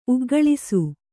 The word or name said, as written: ಉಗ್ಗಳಿಸು